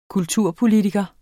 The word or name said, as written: kulturpolitiker